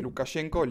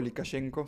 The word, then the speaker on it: Lukashenko: con el presidente Lukashenko, Lukashenko se puede pronunciar de ambas formas, que también está teniendo unas protestas eh, tremendas.